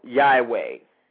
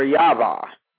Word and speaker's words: Yahweh: This god is called Yahweh, or Yava, has also been another way to pronounce that word.